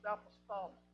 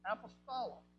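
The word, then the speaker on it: apostolos (ἀπόστολος): The Greek word translated apostle is pronounced apostolos. That's also where we get our name, apostolos. All comes from the same root word. But that's the Greek word, apostolos, and literally means one who is sent.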